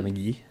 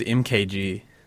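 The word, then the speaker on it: Mk.gee: Rockman by McGee. – Yeah. It's like, yeah. – Oh, that's right. That's how you pronounce it. – Yes. – I never knew. I thought it was MKG.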